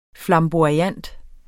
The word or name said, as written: FLAMBOYANT